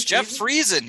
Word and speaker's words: Friesen: It's Jeff Friesen.